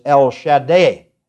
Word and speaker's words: El Shaddai: We speak it El Shaddai. Actually, the Hebrew pronunciation is El Shaddai.